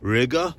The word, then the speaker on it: Riga: Now, Riga, which rhymes with nigga, is the opposite of Oslo, okay? Yes, there's some colored people in Riga, but nowhere near as bad as it is here.